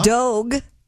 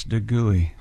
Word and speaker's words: Dogue: Dogue. D-O-G-U-E opened last month at the city's trendy Mission District. – I think that's Dugui. – Man's right. – Says here it rhymes with Vogue, chick.